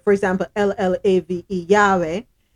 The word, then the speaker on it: llave: It sounds like a Y, right? In some countries. Now, in the Dominican Republic, the LL doesn't sound like a Y. It's pronounced like a J. So instead of saying Yahweh, they say Jave.